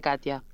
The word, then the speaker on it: Katja: No, mi nombre es complicado, se dice Katia, es mucho más sencillo de pronunciar de lo que aparenta.